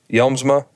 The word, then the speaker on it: Yelsma: Did I say that right? Yelsma. That's it. Emphasize the S.